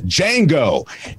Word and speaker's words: Django: And, you know, they'd say they pronounced his name wrong, Django. And he said, it's Django.